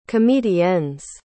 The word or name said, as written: comediennes